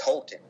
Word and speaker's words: Houghton: Next time someone's in the UP, is it Houghton? How do you pronounce where Michigan Tech is? – It's Houghton.